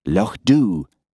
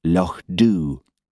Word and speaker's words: Loch Dubh: "What has happened to Loch Dubh?" she demanded. – "Loch Dhu," corrected Hamish gently. "It's pronounced Loch Dhu."